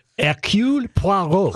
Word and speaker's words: Hercule Poirot: Hercule Poirot.